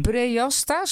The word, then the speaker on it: Breasta: In Breasta, spreek je dat zo uit? – Ja, daar ben jij daar binnen geweest? – Wat zeg je?